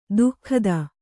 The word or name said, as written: ದುಃಖದ